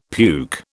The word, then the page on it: puke: British English